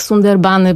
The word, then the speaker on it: Sundarbany: W takim razie powiemy o Sundarbanach. Ja to wymawiam jednak Sundarbany, bo tak znam ten wyraz na początku zapożyczony z angielskiego, więc to jest po bengalsku Sundarbany.